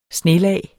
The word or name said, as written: snelag